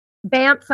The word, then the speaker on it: Banff: Banff, Alberta.